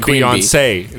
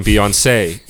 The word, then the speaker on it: Beyoncé: It's you're pronouncing the accent right. You're just putting the syllable in a weird place, so, with the emphasis on a weird place. – So, Wait. – Just call it Beyonce instead of the emphasis on the middle syllable. Beyonce. You're going Beyonce. – Beyonce. – Like fiance.